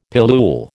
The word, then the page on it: pillule: American English